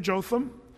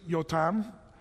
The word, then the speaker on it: Jotham: His name, Jotham, or if you wanted to say it like the Hebrews, Yotam.